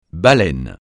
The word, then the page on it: baleine: francais